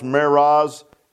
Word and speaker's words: Meroz: it's pronounced Meroz, M-E-R-O-Z, and it's mentioned in the book of Judges in a little song that they were singing.